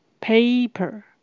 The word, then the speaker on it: paper: 请留意它的发音，paper paper 是发 a 的长母音。有一个字跟它的发音很接近，也就是胡椒，胡椒粉。